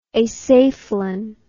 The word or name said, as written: acephalan